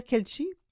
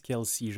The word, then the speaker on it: Kelce: Il faut le dire, Kelchi? C'est comme ça qu'il faut le prononcer? – Travis Kelsey, je crois. – Kelsey. OK, il n'y a pas de H.